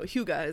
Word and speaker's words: hygge: I just showed Paige the word H-Y-G-G-E, and it is pronounced Hygge.